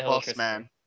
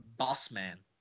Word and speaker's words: Bozeman: Bozeman. – The boss man. – the correct pronunciation is a boss man.